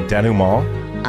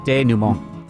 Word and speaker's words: denouement: I pronounce it. You might say it differently, but I say denouement. – I say denouement. – You guys can't even say it. – Long A. Denouement.